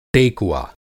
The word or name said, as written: टेकुआ